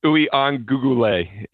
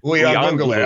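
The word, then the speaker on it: Uiagalelei: It's Ui-an-gugule. It's 6 syllables. Ui-an-gugule. – Ui-an-gugule.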